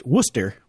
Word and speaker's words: Worcester: Yes, I do believe it's pronounced Worcester.